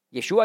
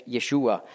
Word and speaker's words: Yeshua: Jeschua ist die hebräische Aussprache für Jesus. Griechisch ist die Aussprache Jesus, Hebräisch Yeshua.